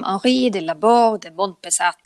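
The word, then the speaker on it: Henri de Laborde de Monpezat: Han föddes som Henri de Labourd de Montbesat. – Oj, vilket uttal! 10 poäng.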